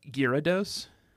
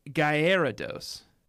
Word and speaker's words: Gyarados: Its name isn't Gyarados. It's Gyarados. – Yeah, but I don't think you pronounce that A. – It's Gyarados. G-Y-A-R-A-D-O-S.